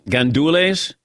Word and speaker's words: gandules: A-R-R-O-Z, con, G-A-N-D-U-L-E-S. Gandules? Arroz con gandules? – Yes, that's rice and pigeon peas.